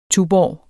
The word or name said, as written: tuborg